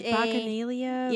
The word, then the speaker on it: Bacchanalia: The theme was, I don't know how to pronounce it. – It starts with a B, A, C, C, C, H, but it's like Bacchanalia.